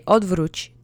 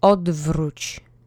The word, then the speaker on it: odwróć: So why don't we just say odwróć together? Try to roll the R if you can. The accent is on the first syllable. Let's repeat together three times. Odwróć.